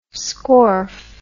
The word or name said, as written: scorf